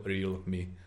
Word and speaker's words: Realme: Má to být Realme, no. Realme, je to okay. takhle jako Realme, takže krátce, na je.